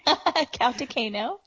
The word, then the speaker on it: Kautokeino: Kautokeino.